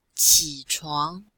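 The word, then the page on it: qichuang: qi3chuang2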